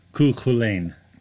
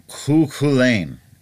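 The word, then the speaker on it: Cú Chulainn: If I could interrupt on a point of pronunciation, that is an old Irish god, and I'm pretty sure it's pronounced Cuchulain. – Cuchulain.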